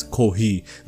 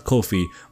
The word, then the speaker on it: Ko-fi: I've been pronouncing it as Ko-hi, not Ko-fi.